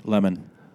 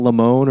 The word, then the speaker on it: Lemon: Is it Lemon or Lehman? – Lemon. – Okay, because I feel like Fox News, they purposely say his last name wrong because they call it Lamone or Lehman or whatever.